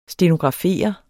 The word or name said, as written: stenografere